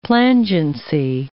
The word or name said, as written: plangency